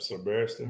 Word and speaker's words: Ser Barristan: Meantime, Ser Barristan. I hope I said his name right. – Yep, Ser Barristan.